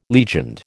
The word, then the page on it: legioned: American English